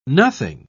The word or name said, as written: nothing